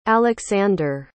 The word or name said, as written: aleksandr